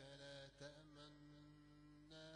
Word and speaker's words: تأمنا: الإشمام يعني ضم الشفتين كأننا قلنا لا تأمنونا. هذه الإشارة تدل على أن هناك نونين أدغمتا أصلهما كانتا ما لك لا تأمنونا فتسهيلا قرأنا لا تأمنا.